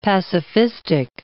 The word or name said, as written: pacifistic